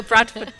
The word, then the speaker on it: Bradford: You're Bradford born, I believe. – You, you, can tell I'm Bradford born because I don't pronounce it with a D. It's with a sort of glottal T.